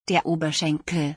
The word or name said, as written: der Oberschenkel